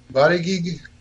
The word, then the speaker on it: Buttigieg: Buttigieg, because in German, the G is, in English, the G most of the time is pronounced as G, like giant or girlfriend. – Say, but in German, it's pronounced G. So it would be Buttigieg. Or Buttigieg, depending on who's pronouncing it.